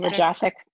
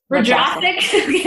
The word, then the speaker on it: rajasic: And like you said, like, hey, you know, I'm feeling inflamed, and was rajavistic. How do you say it? – Rajasic. – Rajasic. There we go.